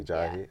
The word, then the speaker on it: Ajayi: Jay Ajayi.